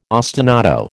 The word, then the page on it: ostinato: American English